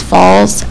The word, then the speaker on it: Falls: My last name is Falls. F-A-L-L-S. – And Rachel's R-A-C-H-A-L? – Mm-hmm.